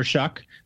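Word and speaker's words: Shough: is Tyler Shuck. It's not spelled like Shuck. It confuses people, but that's how it's pronounced.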